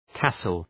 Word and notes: tassel: ‘tæsəl

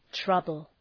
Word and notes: trouble: ‘trʌbəl

